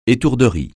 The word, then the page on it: étourderie: français